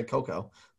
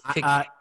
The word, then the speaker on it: cacao: So then of course I get the so that one I'm not sure if the pronunciation is it cacao or do you still say cocoa? – I think it's cacao.